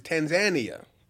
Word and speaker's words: Tanzania: Yo, we supposedly we pronounced Tanzania wrong. It's Tanzania.